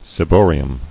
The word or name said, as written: Ciboria